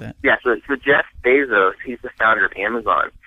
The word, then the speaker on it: Bezos: Yeah, yeah, Bezos.